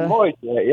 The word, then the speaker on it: Moritat: And it was a moritat, or how you pronounce that. – Yeah, moritat, yeah.